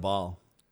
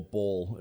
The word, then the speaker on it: Baal: Uh Throne of Baal. – Okay. – Or ball, if you want to pronounce it incorrectly.